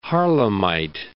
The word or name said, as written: Harlemite